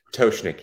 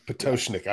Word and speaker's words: Pototschnik: So Pototschnik. – Yep, there you go. – Pototschnik, I got it.